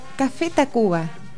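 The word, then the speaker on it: Café Tacvba: ¿Lo dije bien? – Café. – Café Tacuba.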